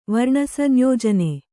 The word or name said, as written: ವರ್ಣಸಂಯೋಜನೆ